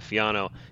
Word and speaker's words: Fiano: Fiano. – I'll say too. I think the special artwork that you talked about on the Fiano, if I'm not mistaken, that was your artwork, right, Jessica? – Yes.